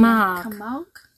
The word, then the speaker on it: Camargue: Camargue.